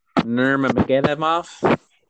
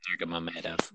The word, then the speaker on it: Nurmagomedov: Nurmagomedov? Just kidding. Nurmagomedov.